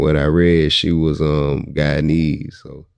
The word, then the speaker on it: Guyanese: Guyanese.